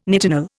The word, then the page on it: nitinol: Indian